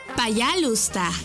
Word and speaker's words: пожалуйста: S-P-A-S-I-B-A. Спасибо. Repite conmigo. Спасибо. ¡Muy bien! Nuestra siguiente palabra es por favor. Y se dice... Пожалуйста. ¿Cómo? Пожалуйста. Una vez más. Пожалуйста. Y se escribe Payalusta. P-A-Y-A-L-U-S-T-A.